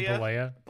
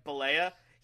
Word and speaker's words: Bollea: Terry, I don't even know how to pronounce his last name. – Terry Bollea. – Bollea.